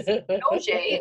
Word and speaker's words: Nogier: And for the first couple of requests, I thought that they were misspelling my name because Noje is spelled N-O-G-I-E-R.